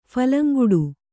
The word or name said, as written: ಫಲಂಗುಡು